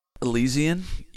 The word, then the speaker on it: Elysian: Elysian. Did I say that right? – Elysian? E-L-Y-S-I-A-N? – Yes.